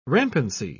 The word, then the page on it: rampancy: British English